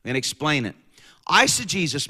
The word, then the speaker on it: eisegesis: So how do you spell eisegesis? It's spelled E-I-S-E-G-E-S-I-S.